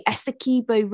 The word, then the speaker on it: Essequibo: You were following the, and excuse my pronunciation, the Essequibo River. – Perfect. Yep, nailed it.